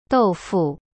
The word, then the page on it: 豆腐: dou fu